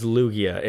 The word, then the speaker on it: Lugia: It is Lugia.